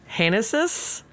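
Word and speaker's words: Génesis: Like Hanesis, because oh, G would be an H. – There is an accent on the first E, so I did screw that up.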